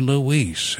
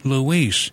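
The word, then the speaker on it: Louiss: the hour, this is the French organist Etty Louise, and these are brand new recordings from the organist Etty Louise.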